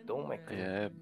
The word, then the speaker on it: Don McLean: Don McLean.